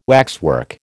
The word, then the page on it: waxwork: American English